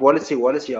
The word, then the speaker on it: Wallace: Spricht man eigentlich Wallacy aus? – Ja, wir haben jetzt gesagt, wir nennen ihn Wallace, aber du kannst das natürlich gerne halten, wie du möchtest.